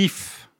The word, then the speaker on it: hyphe: Le mycélium, c'est un vaste réseau de filaments, plus ou moins microscopiques, qu'on appelle les ifs. – Les quoi ? – Les ifs. Alors, pas comme l'arbre. Pas comme l'arbre qui s'écrit IF, mais là, ça s'écrit H-Y-P-H-E.